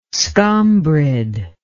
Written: scombrid

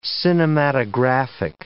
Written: cinematographic

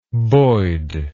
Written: boyd

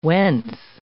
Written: whence

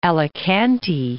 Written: Alicante

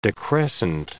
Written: decrescent

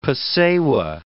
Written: pesewa

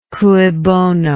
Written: cui bono

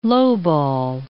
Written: lowball

